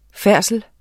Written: færdsel

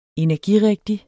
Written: energirigtig

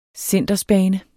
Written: cindersbane

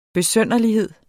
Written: besynderlighed

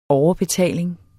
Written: overbetaling

